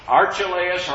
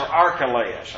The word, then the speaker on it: Archelaus: Archelaus or Archelaus? I've heard it. Archelaus, okay. I've heard it in various recordings even, Bible recordings, pronounced both ways.